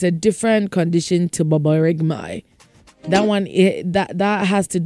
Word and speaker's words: borborygmi: That is baboregmi. It is spelled B-O-R-B-O-R-Y-G-M-I. Baboregmi. Bobo, no. G she inside baboregmi. B-O-R-B-O-R-Y-G-M-I. Baboregmi.